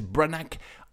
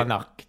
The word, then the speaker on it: Branagh: Thor, c'est un film de super-héros réalisé par Kenneth Branagh en 2011, d'une durée de 1h57. – On va tolérer. Ouais, la prononciation n'est pas ouf. – On va tolérer le okay. Branagh. – Kenneth Branagh.